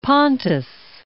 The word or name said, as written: Pontus